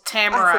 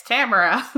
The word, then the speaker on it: Tamora: For anyone else who also read her books and never heard her say her name. – Yeah it's Tamora. But yup.